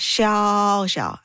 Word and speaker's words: Xiao Xiao: I do not even know how to give people the literal step-by-step instruction because letter X does not sound as H. – Yeah. – And then when you say Xiao Xiao and then you tell them the sound, there's no equivalent sound.